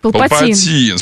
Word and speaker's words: Палпатин: А, ударение на «и». – Палпатин.